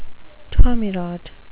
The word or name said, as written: tommyrot